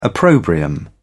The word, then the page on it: opprobrium: British English